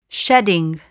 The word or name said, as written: shedding